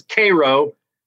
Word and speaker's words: Cairo: Like uh, my my uh, yeah my, my favorite Georgia town is is Cairo, although it's spelled Cairo, and the um the high school there was called the Syrup Makers because Cairo sounded like Karo syrup making or syrup.